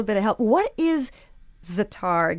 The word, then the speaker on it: za'atar: I mentioned za'atar. Am I saying that correctly? – Za'atar, yeah.